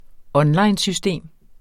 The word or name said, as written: onlinesystem